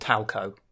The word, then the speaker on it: Talco: T-A-L-C-O? – Yes. – Okay. – It is 100% phonetic. – Because it sounded like you were saying Towelco. T-O-W-E-L-C-O.